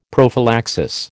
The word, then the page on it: prophylaxis: American English